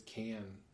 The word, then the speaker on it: Cannes: You want me to correct you? That it's Cannes. – Can, whatever. – That's how you say it.